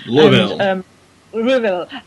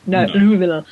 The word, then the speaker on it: Louisville: Louisville. – And yes, not spelled, not pronounced as I thought. Louisville. No. Louisville.